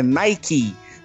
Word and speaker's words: Nike: do Nike é Nike, né? – É, é, uhum.